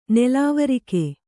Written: ನಲವರಕೆ

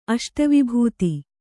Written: ಅಷ್ಟವಿಭೂತಿ

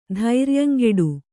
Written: ಧೈರ್ಯಂಗೆಡು

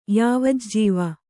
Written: ಯಾವಜ್ಜೀವ

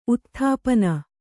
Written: ಉತ್ಥಾಪನೆ

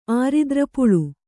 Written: ಆರಿದ್ರಪುಳು

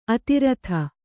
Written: ಅಧರಿತ

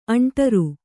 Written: ಆಂಡಾರಿ

